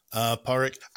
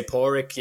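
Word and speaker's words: Pádraic: You can say Porrick. You can say Porrick like pour me some milk. You can say Porrick like a dog's paw, but the D you never pronounce.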